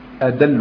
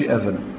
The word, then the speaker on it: أدل: معناها أدل، أدل بالدالة مش أذل، أدل أي أكثر دلالة، أنطق.